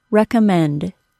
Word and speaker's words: recommend: For instance, the word recommend. The third syllable is stressed. Recommend. So we can expect a secondary stress on the first syllable.